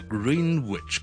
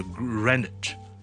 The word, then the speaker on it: Greenwich: 再嚟一个格林威治，我哋格林威治，Greenwich，我哋就唔系读 Greenwich 嘅，我哋读 Greenwich。